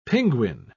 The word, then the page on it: pinguin: British English